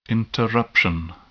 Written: interruption

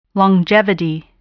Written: longevity

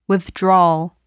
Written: withdrawal

